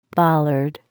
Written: bollard

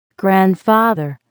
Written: grandfather

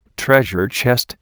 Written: treasure chest

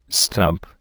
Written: stump

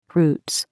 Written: roots

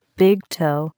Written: big toe

